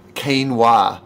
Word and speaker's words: quinoa: And the waiter said, "Quinoa." Keen as in eager, wah as in the sound that comes after David Caruso does a punchline on CS.